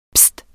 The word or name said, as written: pist